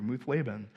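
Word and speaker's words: Muth-labben: This is what literally how to pronounce it in Hebrew, Muthlaban.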